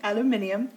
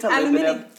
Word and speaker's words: aluminium: it's aluminum. And, and they pronounce it not how it's spelled. That's what's really annoying to me. They put an extra I in there, which is really annoying.